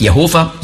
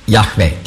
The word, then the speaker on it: Jahwe: Das ist also nicht Jehova, sondern Jahwe die richtige Aussprache ist. Nicht Jahwe. Jahwe ist ein hörbares H. Auf Hebräisch sehr wichtig.